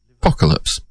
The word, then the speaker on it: Apokalippps: You follow it up with a group named Apocalypse. Except it's spelled A-P-O-K-A-L-I-P-P-P-S. Apocalypse with lips having three P's.